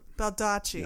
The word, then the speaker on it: Baldacci: I think Baldacci is how you say it. – Baldacci.